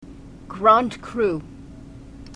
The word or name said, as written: grand cru